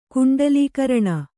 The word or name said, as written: ಕುಂಡಲೀಕರಣ